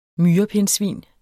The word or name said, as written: MYREPINDSVIN